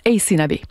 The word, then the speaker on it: Aysanabe: Alors, je vous épelle son nom, c'est A-Y-S-A-N-A-B-E. Ça se prononce Ace Nabé.